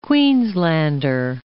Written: Queenslander

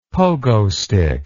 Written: pogostick

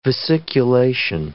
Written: vesiculation

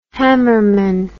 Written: hammerman

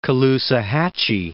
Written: Caloosahatchee

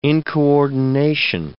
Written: incoordination

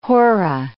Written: horah

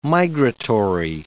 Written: migratory

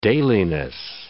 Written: dailiness

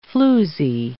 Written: floozie

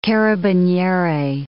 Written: carabiniere

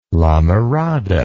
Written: lamirada

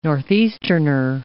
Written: Northeasterner

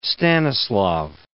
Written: Stanislaw I

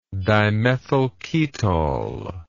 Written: dimethylketol

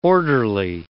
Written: orderly

